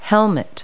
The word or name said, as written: helmet